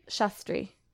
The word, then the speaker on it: Shastri: And how you say it? Shastri? – Shastri.